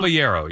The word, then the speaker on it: Caballero: And I looked it up, Caballero, is that how you say his name? – Caballero, yes.